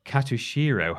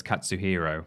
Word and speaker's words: Katsuhiro: On the PSN store, catching the attention of Tekken director Katsuhiro Harada. I think I said that right. Oh, no. No, it's Katsuhiro.